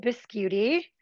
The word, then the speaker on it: Biscuiti: And so my name's Nicole Biscuiti. It's spelled biscuit with an I at the end, which I always have to explain to anyone I'm talking to on the phone.